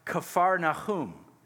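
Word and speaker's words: Kfar Nahum (כפר נחום): And the reason for that is in Hebrew, Capernaum is actually pronounced Kfar Nahum, which is the village of Nahum, or the village of comfort.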